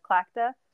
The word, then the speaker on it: Tlachtga: So, for example, in Ireland, the ancient bonfires can be traced back to clacta, which is another word I had to learn how to pronounce because it's spelled T-L-A-C-H-T-G-A.